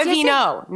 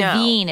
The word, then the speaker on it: Avène: But other than that, I'm using all, and I'm going to say it wrong, Avène? No, yes, not a Aveeno. Not a Aveeno. – It's Avène. It's like a French brand, but it's like, A-V-E-N-E? – Yeah, with a little, maybe an accent.